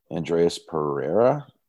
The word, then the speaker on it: Andreas Pereira: Andreas Pereira. Derek, is that how you pronounce it? – Yeah. – Great.